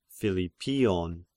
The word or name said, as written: Philippion